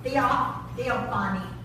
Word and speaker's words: Theophany: Theophany. – Theophany. I said it right the first time. – Yep, you did.